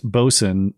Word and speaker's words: boatswain: Injured Guardian says blonde boatswain is an important job and it's pronounced bosun.